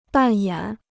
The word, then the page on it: 扮演: bàn yǎn